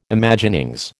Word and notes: Imaginings: American English

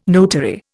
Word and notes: notary: Indian

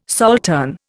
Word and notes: saltern: Indian

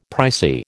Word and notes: pricey: American English

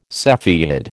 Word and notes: cepheid: American English

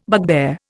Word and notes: bugbear: Indian

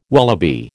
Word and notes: wallaby: American English

